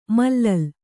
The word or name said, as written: ಮಲ್ಲಲಿ